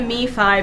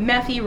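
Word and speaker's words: MeFi: I've always been a me-fi, but I heard someone calling it meh-fi, rhyming with that kid from Family Circus, and I think that's funny and charming, but wrong.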